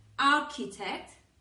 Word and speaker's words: architect: In architect, CH is K, es decir, en arquitecto la CH se pronuncia como una K, architect, como en español architect.